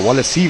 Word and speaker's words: Wallace: Mit der 8 Wallace, der ist auch noch, oder Wallace C wird er ja ausgesprochen, auch noch relativ bekannt.